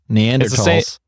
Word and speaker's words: Neanderthals: Neanderthals. – It's the same thing. You just don't pronounce the TH. It's just talls.